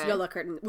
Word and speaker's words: Jólakötturinn: It's spelled like that, but it's pronounced Jólakötturinn, which is Jólakötturinn with some fun accents over the umlauts.